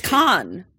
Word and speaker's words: Cannes: Well, yes, because you only pronounce up to, yes, it's Cannes.